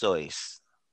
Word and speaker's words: Seuss: My nigga, it's pronounced Seuss.